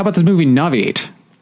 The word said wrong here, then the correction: Novitiate: You were definitely skipping over some syllables.